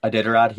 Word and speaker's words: Iditarod: He he filmed something in Alaska, I think called the uh, Iditarod, or I forget how it's pronounced. – Iditarod, yeah.